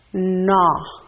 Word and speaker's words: gnaw: The last one on this slide is the word gnaw. Gnaw. In this one, we do not, the consonant G is silent, so we just say gnaw.